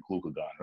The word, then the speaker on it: glucagon: All right, so your three main catabolic hormones, so those are cortisol, adrenaline, and glucagon. I'm definitely saying that right, glucagon, right? – Yeah, glucagon, yep.